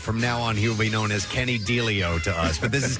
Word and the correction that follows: Delio: So correct us on the pronunciation, if you would. – It's Delio. – It's Delio. Delio. Well, we were going. – With Delio? – We're going with Delio.